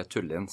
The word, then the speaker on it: Tullin: Och vi sitter ju nu på, eh, eller i Tullin eh, området, eller Tullin som jag alltså förstår att det, det, det uttalas som.